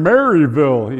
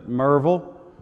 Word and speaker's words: Maryville: I was told by some locals, don't get up there as a tourist and say, I'm glad to be here in Maryville. He says, they don't pronounce it Maryville here. I said, well, how do they pronounce it? He said, Merville. I said, Merville? I said, yeah, Merville.